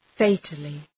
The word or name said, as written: fatally